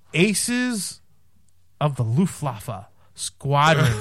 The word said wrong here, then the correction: Luftwaffe: It's, I don't think you said that right. The Luf, the Luftwaffe.